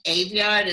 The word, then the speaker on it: Aveyard: And my last name, Aveyard, is spelled Avenue Yard, A-V-E-Y-A-R-D.